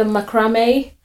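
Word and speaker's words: macramé: When I watched one of her videos, she did a macrame. Am I saying it right? Macrame, right? – Yeah. – Yeah. – As far as I know.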